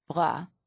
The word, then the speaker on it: bras: The French word bras, with a silent S, means arm.